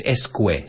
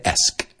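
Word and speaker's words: -esque: El esco en inglés es escue. Se escribe, pero se dice esque.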